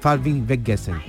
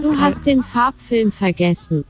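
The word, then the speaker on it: Du hast den Farbfilm vergessen: ¿Cómo, cómo se pronuncia? – Se pronuncia, vamos, lo que yo pronuncio, Falvin Weggessen. – Te lo, voy, te lo voy a poner mejor, verá, ahí hay un sonido que, a ver, a ver. Du has den Farbfilm vergessen. Du hast den Farbfilm vergessen. Así se pronuncia.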